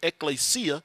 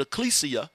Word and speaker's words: ekklesia: The word church comes from the Greek word ekklesia. Some people pronounce it ecclesia, and it means God's called out people.